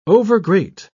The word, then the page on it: overgreat: British English